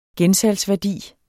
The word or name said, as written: gensalg